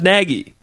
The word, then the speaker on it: Nagy: Like, the caller, we just had a caller who said his name was Nagy. – Yeah. What helps me is reminding myself it rhymes with Peggy. – Yeah. Or egg. – Peggy, Nagy, Peggy.